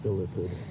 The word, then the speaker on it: illithid: So, Matt hurriedly runs toward the door that the Dylan Ithalid points at. Illithid. Yeah. I said it wrong the first time.